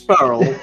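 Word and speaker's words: Faerl: Furl. Like unfurl.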